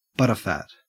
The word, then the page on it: butterfat: Australian English